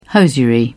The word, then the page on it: hosiery: British English